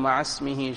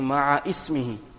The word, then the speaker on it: مع اسمه: Many a times people pronounce it incorrectly by saying ma'a ismihi. Correct pronunciation as we are reading it.